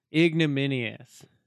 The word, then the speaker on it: ignominious: You know what's a word, that I've realized when I say it out loud sounds ludicrous, and I'm convinced that I'm saying it wrong, but I'm not. – What's that? – Ignominious. – Ignominious.